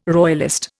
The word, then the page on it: royalist: Indian